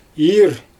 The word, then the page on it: iir: Xyzyl